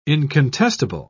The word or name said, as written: incontestable